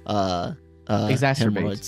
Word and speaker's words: exacerbate: The sphincter and can exasperate. – Exacerbate. – So, how did I say it? Did I say it wrong? – You said exacerbate.